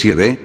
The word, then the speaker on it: Yahvé: Por eso es que en este librito y en el resto de libros míos la pronunciación que utilizo siempre es Yahvé, en hebreo arcaico, para referirme al nombre de estos dos dioses eternos, el Padre y el Hijo.